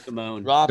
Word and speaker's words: Simone: If you're a huge athlete, this person, Simon Biles, is that how you pronounce her name? – Well, I thought this person was just Simone. Biles.